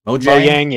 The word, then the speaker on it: Mojang: Microsoft did it with Mojang, or however you pronounce that company's name. – Mojang? – Mojang, yeah.